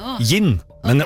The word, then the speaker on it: jinn: Nei, ja, det skrives med jod. J-I-N-N. Gin. Men okay. Også det uttales gin, for på engelsk så skrives det med D først. Gin.